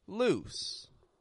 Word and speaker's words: loose: Whereas loose, loose, is very quick in the vowel. And it goes straight into an S.